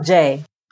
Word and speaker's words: Jea: Is it John Jay? Okay. I went back and forth a million times when I was reading it. Okay, so John Jay, which is spelled J-E-A for the last name.